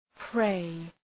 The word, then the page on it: prey: preı